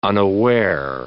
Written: unaware

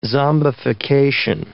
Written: zombification

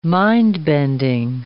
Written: mind-bending

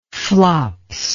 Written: flops

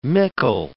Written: mickle

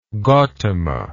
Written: Gotama

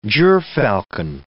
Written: gyrfalcon

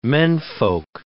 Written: menfolk

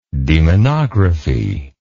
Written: demonographer

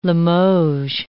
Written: Limoges

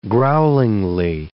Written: growlingly

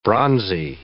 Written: bronzy

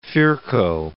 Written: Virchow

